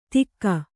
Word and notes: ತಿಕ್ಕ: tikka

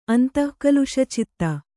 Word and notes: ಅಂತಃಕಲುಷಚಿತ್ತೆ: antahkaluṣacitte

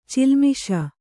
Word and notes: ಚಿಲ್ಮಿಷ: cilmisha

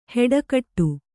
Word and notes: ಹೆಡಗಟ್ಟು: kannada

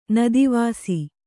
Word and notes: ನದಿವಾಸಿ: kannada

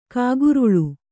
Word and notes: ಕಕ್ಕೊರಲೆ: kakkorale

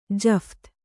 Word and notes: ಜಫ್ತಿ: Kannada

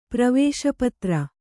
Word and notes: ಪ್ರವಾಸಪತ್ರ: pravāsa patra

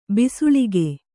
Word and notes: ಬೆಸಳಿಗೆ: kannada

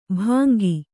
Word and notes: ಭಂಗಿ: Kannada

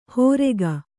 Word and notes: ಹೋರೆಗ: hōrega; Kannada

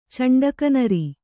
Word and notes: ಚೆಂಡಕನರಿ: ceṇḍaka nari

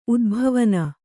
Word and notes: ಉದ್ಭವನ: udbhavana

